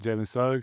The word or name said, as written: Jalen Suggs